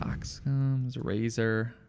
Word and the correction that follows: Occam's razor: It's a razor. I'm saying it wrong. Occam's razor.